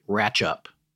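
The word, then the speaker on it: Rattlinghope: I guess Ratlinhope is pronounced locally Ratchup.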